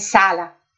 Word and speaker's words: Sala: Mon nom est Sala.